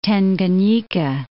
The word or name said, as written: Tanganyika